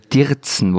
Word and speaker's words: dirzen: Wir sitzen nicht, sondern wir dirzen, wobei das, oder habe ich das richtig ausgesprochen? Dirzen. Dirzen.